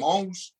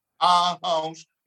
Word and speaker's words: Aarhus: I'm from Aarhus. Aarhus. In the middle of the street, you know, that's how to pronounce it. I heard you were having trouble pronouncing it, but that's our house, Aarhus, you know, so that's where I'm from, and I've lived all my life.